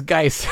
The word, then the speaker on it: Geis: My name is Geis. – Gage Geis. – It's supposed to be first syllable of your name plus ice.